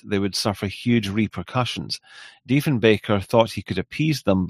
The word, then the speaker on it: Diefenbaker: And then Kevin says, my grandfather was a personal friend of, I might mispronounce this, Diefenbaker? Diefenbaker? – Diefenbaker, yes.